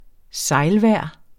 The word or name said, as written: sejlvejr